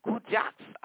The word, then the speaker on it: Kuijox: I got some more music from the artist born named Jose, but his stage name, let me spell that again, is K-U-I-J-O-X, Kujax.